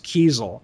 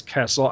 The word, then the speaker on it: Kiesel: But also, it turns out that, you know, I feel like I really owe you an apology, because she said that their name is pronounced Kiesel. I've always said it was Kessel. I've insisted all these years that it was Kessel.